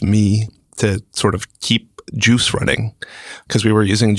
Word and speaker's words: Guice: Juice is, oh, and I should say it's Juice pronounced, it's spelled G-U-I-C-E if you're Googling it. Mm-hmm. So it's Juice and it's pronounced like GIF.